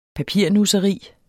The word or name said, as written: papirnusseri